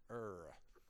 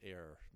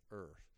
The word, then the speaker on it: err: By the way, that's the correct way to pronounce it, I was told, err. I thought it was err. No, it's err.